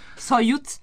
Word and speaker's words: Soyuz: Naturalmente è partito con una navicella che adesso non riesco a pronunciare quella parola lì, quella Soyuz.